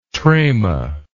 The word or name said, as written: trama